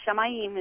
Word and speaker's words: Shamayim: The Shamayim is how you say heaven, and the earth, or the Eretz, how you say earth in Hebrew is Eretz.